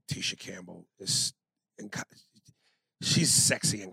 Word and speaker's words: Tisha Campbell: Tisha Campbell.